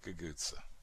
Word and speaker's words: cucuzza: And one of the evidence of that is Guguzza. C-U-C-U-Z-Z-A. Guguzza, that's of course. Did I say it right? Guguzza.